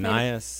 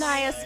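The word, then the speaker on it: Anaïs: I can't pronounce her first Anais. – Name. Anais.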